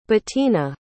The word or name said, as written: Betina